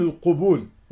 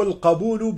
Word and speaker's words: القبول: وهنا فائدة بين قوسين: مصطلح القبول نستعمله نحن كثيرا في علم مصطلح الحديث، وبعضهم يخطئ في نطقه فيقول القبول، وهذا خطأ، إنما هو القبول بفتح القاف، من قبل يقبل قبولا، وأما القبول فهو جمع قبلة، وهو خطأ.